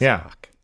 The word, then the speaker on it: Bach: Do you pronounce the first one the same way Paul does? He's like, Bach. He's really got the little back of the throat. – I assume it's the German. I don't. Is he German? – Bach. I do not pronounce it that way. It's just Bach.